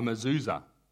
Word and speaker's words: mezuzah: What they do is they, forgive me if I pronounce this wrong, a mezuzah. Have I got that? I got it right.